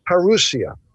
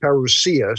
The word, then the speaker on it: parousia: The Greek word here is parousia. Strong's 3952. It's also pronounced parousia, so if you hear it either way, it's just variations on pronunciation.